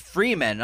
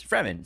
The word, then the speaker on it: Fremen: It's Fremen. It's fucking pretty clear. It's one E. It's not Freeman.